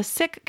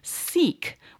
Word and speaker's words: Sikh: As a side note, I read up on it, and the Sikh community prefers this pronunciation to Sikh, which I frequently heard in the past, so I'm going with Sikh.